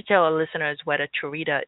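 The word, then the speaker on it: Cherita: Charita, pronounced just like a chair, Rita, is a short verse that tells a story.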